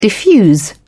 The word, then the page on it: diffuse: British English